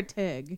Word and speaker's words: Tadhg: Was it Tag or Tig? – Yeah.